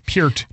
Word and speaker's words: Peart: I don't mean to be that cruel, because like, Peart could take another step forward at right tackle, which, by the way, I learned the right pronunciation for that name from this podcast. – Peart. Yeah.